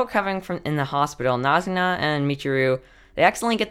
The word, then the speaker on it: Nazuna: Did I say that right? Yep, Nazuna.